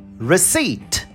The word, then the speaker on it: receipt: R-E-C-E-I-P-T. A gente não fala receipt, mas receipt. Vamos comigo?